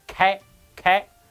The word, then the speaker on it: K: 上海人讲 “K” 这个字的英文呢，都会发成开开，跟上海人打扑克牌，黑桃 K，他会念成黑桃老开，因为黑桃老 K 啊，一脸有钱人的样子，所以呢，又用来代指有钱的老头。